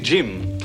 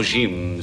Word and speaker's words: Jim: Il faut prononcer Jim à l'anglaise avec un D devant. Non, Jim, ça ne lui ressemble pas.